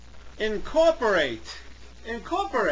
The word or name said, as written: incorporate